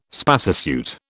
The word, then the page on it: spacesuit: British English